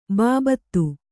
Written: ಬಾಬತ್

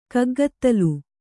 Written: ಕಗ್ಗತ್ತಲ್

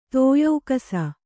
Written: ತೋಯೌಕಸ

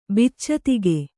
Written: ಬಿಚ್ಚತಿಗ

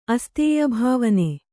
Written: ಅಸ್ತೇಯಭಾವನೆ

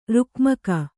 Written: ರುಕ್ಮಕ

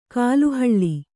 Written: ಕಾಲುಹೊಳೆ

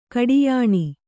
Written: ಕಡಿಯಣ

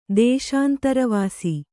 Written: ದೇಶಾಂತರವಾಸ

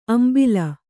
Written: ಅಂಬಾಲ್

